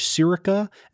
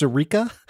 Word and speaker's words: Sirica: Number one, I described the first judge in our Watergate segment as Judge Sirica, and a bunch of folks wrote in to say that it is pronounced Sirica.